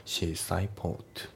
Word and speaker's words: seaside: で、あと発音ですけど、シーサイドのその最後の DE の部分がね、ほとんど発音されてない感じのリダクションになってますね。だからシーサイドポート、シーサイドポートじゃなくてシーサイドポートですね。